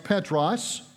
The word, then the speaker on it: Petros: The word Peter is the translation of a Greek word that is pronounced Petros. You hear the word Peter in it. And the word Petros means little rock, little pebble, little stone.